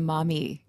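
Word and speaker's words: Maumee: Is it Mame? – The Mami. – Mami, yes. As in Mami.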